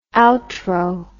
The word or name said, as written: outro